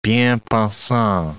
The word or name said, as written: bien-pensant